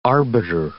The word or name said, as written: arbiter